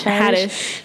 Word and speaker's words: Haddish: Haddish.